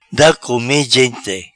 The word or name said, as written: Da come gente